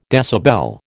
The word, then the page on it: decibel: American English